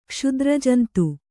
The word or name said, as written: ಕ್ಷುದ್ರಜಂತು